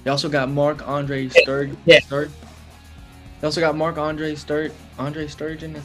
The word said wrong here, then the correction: Stegen: I think that's how you say it. Stegen. Okay.